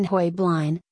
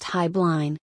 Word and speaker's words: Heublein: The correct pronunciation of the tower is debated. The Heublein family came from Germany, whence the pronunciation Heublin, but after immigrating to the United States, over the years it was pronounced Heiblein. After Heublein Incorporated was bought by the R.J. Reynolds Tobacco Company in 1982, Advertising was run on The Tonight Show with Johnny Carson, who pronounced it Hugh Blind.